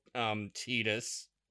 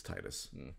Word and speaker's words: Titus: And then I have to explain who all the Square characters are to her, you know, because she's not going to know Titus, which I have now learned is the pronunciation of his name. But ugh, it's Titus.